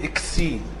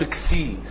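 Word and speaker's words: كسي (ξι): ما فيهاش فتحة من الاول، يعني في ناس بتقول اكسي، لا مفيش الهمزة اللي في الاول، هي حرف كسي.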